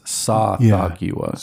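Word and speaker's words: Tsathoggua: When you heard that, those syllables being chanted, it was Sa-Thogua.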